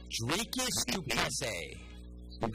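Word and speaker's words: Dricus Du Plessis: Dricus Duplessis.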